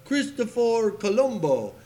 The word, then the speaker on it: Cristoforo Colombo: I don't know the rest of that poem, I, but I, I think for sake of understanding, uh, in Italian, it's pronounced Christoph, Cristoforo Colombo.